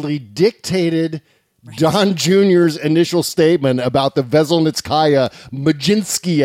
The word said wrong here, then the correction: Magnitsky: Act meeting. Magnitsky. See, now you got me saying it wrong.